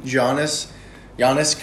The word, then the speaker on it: Giannis: To be honest, him and Giannis could be a great combo. – You said it right the first time.